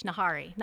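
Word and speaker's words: Nahri: Although sometimes they say it with an H, Nahari. Nahari. – And it's spelled with an H. Okay. It's N-A-H-R-I.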